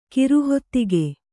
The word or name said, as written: ಕಿರುಹೊತ್ತಗೆ